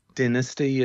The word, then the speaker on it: dynasty: Dynasty is how it's pronounced in the UK and Ireland.